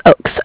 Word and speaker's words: Ochs: It's Ochs, O-C-H-S. – O-C-H-S, and it's Stephanie, it's Stefania? – Yes. – It's spelled S-T-E-P-H-A-N-I-A? – Yes, exactly.